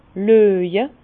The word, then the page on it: löje: Bas-Rhin; Herrlisheim